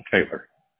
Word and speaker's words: Taylor: Taylor. – Taylor. – That's what you're doing, yeah. – And that's T-A-Y-L-O-R? – Yeah.